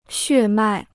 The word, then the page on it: 血脉: xuè mài